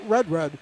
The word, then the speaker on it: Rudrud: Quinn Rudrud, R-U-D-R-U-D is how his last name is spelled, pronounced not Rudrud, but Rudrud.